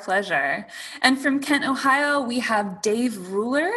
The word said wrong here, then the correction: Ruller: It's Dave Ruller, but that's okay. – Ruller. Glad I asked.